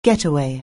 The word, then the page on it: getaway: anglais